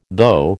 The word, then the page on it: though: American English